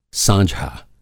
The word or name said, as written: साँझा